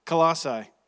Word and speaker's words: Colossae: Colossae was doing. Am I saying that right? I still can't. I'm from Texas. Say it again. Say it again. Colossae.